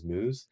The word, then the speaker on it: Meiwes: Armin Meus. Yeah, it's spelled like M-E-I-W-E-S, but the actual German enunciation is Meus.